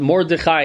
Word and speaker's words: Mordechai: Shmuel Mordechai, some of the Korain types put an extra Mordechai, but Mordechai is how I was raised to pronounce it.